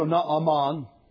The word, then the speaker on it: Naaman: We call him Naaman. Two syllables in the Hebrew, Naaman.